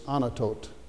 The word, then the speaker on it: Anathoth: But put with this also Jeremiah 1 and verse 1, that tells you that Jeremiah was a priest from Anathoth. And really what happens linguistically is the TH hardens, and so the way you really pronounce that now is not the way it looks. It's Anatot.